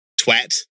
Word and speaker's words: twat: I like the extra British pronunciation of it where it's twat.